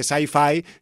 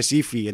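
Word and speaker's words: SYFY: SIFI, que siempre en Donosti se hace la broma de que no se dice sci-fi, sino que se dice SIFI.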